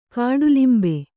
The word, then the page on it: ಕಾಡಲಿಂಬೆ: kāḍalimbe